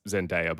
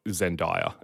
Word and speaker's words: Zendaya: But as part of the press tour, I don't know if you saw this, but Zendaya, who I only just learned that it's pronounced Zendaya, by the way. I thought Zendaya, and it's been ruining me.